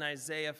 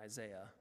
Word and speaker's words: Isaiah: And he models his parable after the one told in Isaiah 5. Yes, I say that as an American, Isaiah, rather than the proper way to pronounce it, Isaiah.